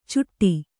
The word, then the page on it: ಚುಟ್ಟಿ: cuṭṭi